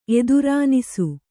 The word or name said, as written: ಎದುರಾನಿಸು